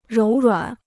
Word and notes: 柔软: róu ruǎn

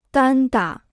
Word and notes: 单打: dān dǎ

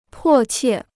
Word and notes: 迫切: pò qiè

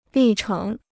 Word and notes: 历程: lì chéng

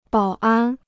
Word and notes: 保安: bǎo ān

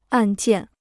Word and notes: 按键: àn jiàn

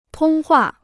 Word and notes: 通话: tōng huà

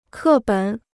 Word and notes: 课本: kè běn

